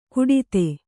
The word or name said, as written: ಕುಡಿತೆ